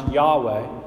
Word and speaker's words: Yahweh: And the name of God, which we pronounce Yahweh, is actually referenced, used 6,800 times in the Old Testament.